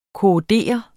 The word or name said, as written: korrodere